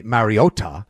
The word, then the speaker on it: Mariota: Like you said, number one, you can hold down even after last night's, oh God, I've never wanted Marcus Mariota to complete, Mariota.